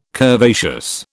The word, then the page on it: curvaceous: British English